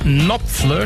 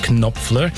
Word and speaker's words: Knopfler: Der Mr. Knopfler, das K vom Nachnamen wird eigentlich nicht ausgesprochen, aber wegen der Bindung mit dem Vornamen wirkt es häufiger so bei Mark Knopfler.